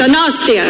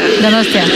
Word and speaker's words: Donostia: How do I say it? Donostia. – Donostia. – Donostia. Okay, yeah.